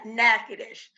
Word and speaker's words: Natchitoches: Natchitoches.